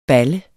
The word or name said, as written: balle